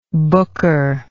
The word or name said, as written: booker